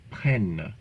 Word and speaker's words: prennent: We hope you noticed the distinct pronunciation of the two stems used for the subjunctive of prendre, for the first, second, and third singular, and the third plural. It's an open E sound, ils prennent.